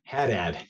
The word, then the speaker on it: Haddad: And if you found this interview with Doug Haddad, Haddad, say your last name again. – Yeah, Haddad, you got Haddad. – It. – Haddad, I don't know why I wanna say ha, it's like Nevada, Haddad.